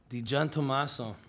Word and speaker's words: DiGiantommaso: DiGiantommaso.